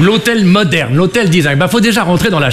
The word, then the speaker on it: design: désigne. Alors je sais qu'il vaut mieux prononcer à la British, hein, le design. Parce que si on dit désigne, bah, on passe pour un plouc.